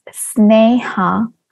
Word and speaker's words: Sneha: My name is pronounced as Sneha Kemp.